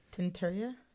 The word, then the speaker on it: tienuurtje: Of a word spelled T-I-E-N-U-U-R-T-J-E. – I think... Oh, actually, that J is probably pronounced more like a Y. – Oh, Turia. Okay. – Tinturia? – Yes.